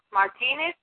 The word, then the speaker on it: Martinez: My name is Professor Solange Martinez, and I'll spell it for you. It's asking Sam, O-L-A-N-G-E, Solange, Martinez, M-A-R-T-I-N-E-Z.